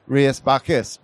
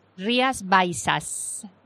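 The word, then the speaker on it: Rías Baixas: Now, I've always said it as Rías Bajas, but you're saying it's about said... – Rías Bajas. – Bajas. – It's a little bit difficult maybe for you, no? – Yeah, I've been saying it wrong for years.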